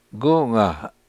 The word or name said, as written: gunga